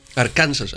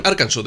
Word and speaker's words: Arkansas: Arkansas. Λοιπόν, Arkansas δεν προφέρετε. – Arkansas, ναι. – Γιατί προφέρετε Arkansas? – Δεν ξέρω, ρε φίλε. Στην Ελλάδα είναι Arkansas πάντως.